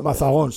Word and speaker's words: Mazagón: Va, pues Mazagón, lo he dicho bien. – Entonces. Mazagón, sí, lo ha dicho usted bien.